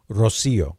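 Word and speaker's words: Rocío: R-O-C-I-O. – Rocio.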